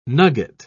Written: nugget

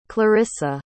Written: clarissa

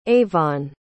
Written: avon

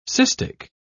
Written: cystic

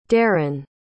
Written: daran

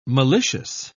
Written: malicious

